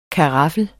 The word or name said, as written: karaffel